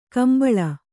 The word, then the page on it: ಕುಂಬಳಿ: kumbaḷi